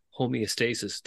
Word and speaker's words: homeostasis: It's a psychological homeostasis. Did I pronounce that correctly? – Yes. Yes.